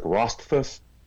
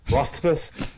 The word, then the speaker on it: Rothfuss: Other fantasy writers, especially modern ones, Patrick Rothfuss, I just butchered his name, Rothfuss,